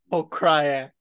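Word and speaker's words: Pokrajac: He has a win over a guy named Igor Pokryak. – No way. – Igor, and it's spelled P-O-K-R-A-J-A-C. I'm pretty sure the J is like a Y. Igor Pokryak.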